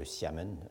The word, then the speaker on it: Xiamen: Et son principal point d'appui était la ville de Amoy, ou Xiamen euh, euh, en prononciation mandarinale, qui est encore aujourd'hui une très grande ville.